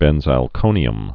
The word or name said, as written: benzalkonium chloride